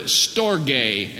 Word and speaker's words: storge: Then there is a second word for love, and that is the word storge, and we spell that in the transliteration into English, S-T-O-R-G-E.